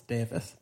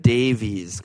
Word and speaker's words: Davis: A billion percent That's how you pronounce this last name with all these fucked up vowels. It's Davis. – Where did Davies come? – Probably me, because I'm an asshole.